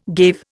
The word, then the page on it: gave: Indian